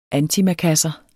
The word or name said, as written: antimakassar